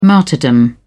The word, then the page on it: martyrdom: British English